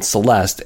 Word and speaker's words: Zeleste: The name Zeleste is actually spelled with a Z, and it's Z-E-L-E-S-T-E, but it is called Zeleste.